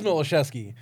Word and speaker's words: Miloszewski: Well, it is Milosevsky. – Okay. – But Walt and everyone else on Tell Him Steve Dave calls it Milosevsky, so...